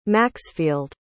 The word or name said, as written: Maxfield